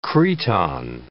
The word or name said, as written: cretonne